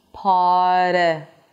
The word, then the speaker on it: party: So you want to insist on hitting that position. Par, par, party. So hold out the R, make sure that you're actually pronouncing the R, and then move on to the flap T.